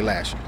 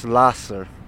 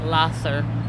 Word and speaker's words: Lahser: Even though it's spelled L-A-H-S-E-R? – Yes. – Lasser. – Oh, really? Yeah. So it's not Lasher. – No, it's Lasser.